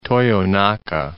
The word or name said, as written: Toyonaka